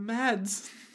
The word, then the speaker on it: Mads: Oh, but Mads. – Mads. – Good old Mads. – Apparently it's more accurately pronounced something like Mars.